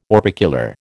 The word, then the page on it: orbicular: American English